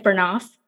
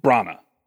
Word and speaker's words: Branagh: Kenneth Branagh. Is that how you say his name? – Branagh.